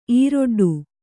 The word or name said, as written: ಈರೊಡ್ಡು